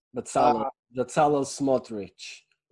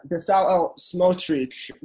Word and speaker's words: Bezalel Smotrich: 2 days ago, Knesset member Benzal, forgive me for butchering this name, Smotrich. – Bezalel Smotrich. – Yeah, Bezalel Smotrich.